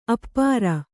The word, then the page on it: ಆಪೂರ: Kannada